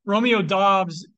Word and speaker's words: Doubs: Romeo Dubs, Romeo Dobbs. Sorry, he told us how to pronounce it today. Romeo Dobbs in Green Bay has been turning heads, and Aaron Rodgers seems to be reportedly really happy for him.